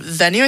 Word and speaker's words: VNUE: Venue, I think is how you pronounce it. It's spelled V-N-U-E, and they specialized in making archives of live concert recordings.